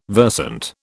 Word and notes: versant: British English

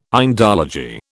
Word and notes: Indology: British English

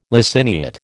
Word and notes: laciniate: American English

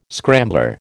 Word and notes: scrambler: American English

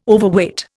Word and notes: Overweight: Indian

